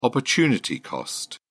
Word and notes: opportunity cost: British English

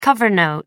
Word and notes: cover note: American English

